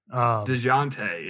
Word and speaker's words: Dejounte: I didn't want to butcher it too hard, but DeJounte, is that what we decided? – DeJounte, yeah.